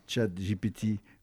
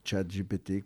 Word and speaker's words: ChatGPT: Mais pour avoir fait une petite expérience en prenant quelques épîtres ou quelques lectures de l'Évangile, pour demander à ChatGPT ou ChatGPT, comme vous souhaitez le prononcer, un résumé, franchement, il n'y avait pas de contresens et c'était...